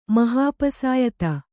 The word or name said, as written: ಮಹಾಪಸಾಯಿತ